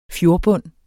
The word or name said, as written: fjordbund